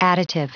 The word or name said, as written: additive